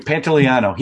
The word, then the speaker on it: Pantoliano: Pantoliano. – Thank you. Pantoliano.